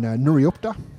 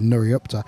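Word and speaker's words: Nuriootpa: The Chusna Winery, based in Nuriupta in South Australia, just up from the centre of the Barossa there in Tanunda. So yeah, very well pronounced. I'm very impressed with Nuriupta.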